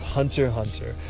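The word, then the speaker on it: Hunter × Hunter: I'm not sure which is the right meaning, but that's how it's pronounced. Hunter hunter.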